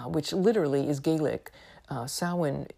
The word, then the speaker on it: Samhain: Samhain um, and it looks like Samhain, but that's not how you say it. You know how Gaelic is; it doesn't resemble anything in reality.